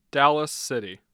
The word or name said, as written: Dallas City